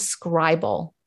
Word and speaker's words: Scrible: They call it Scribble, but it's spelled Scribble. So it's spelled S-C-R-I-B-L-E.